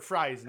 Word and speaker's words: Fries: You mean Victor Fries? No, yeah. No. Not, it's spelled Fries, but you call it Freeze.